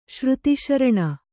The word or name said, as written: ಶ್ರುತಿಶರಣ